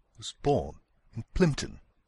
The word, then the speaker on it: Plympton: Ici, il fallait noter l'accent sur la première syllabe de Reynolds, juste avant le R, le mot born, juste avant le B, et la première syllabe de Plympton, juste avant le premier P.